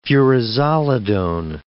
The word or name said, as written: furazolidone